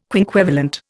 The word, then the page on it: quinquevalent: Indian